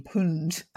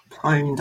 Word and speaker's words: pwned: Have I Been PUND? I'm not sure what it stands for. Pwned. Pwned, I believe you pronounce that.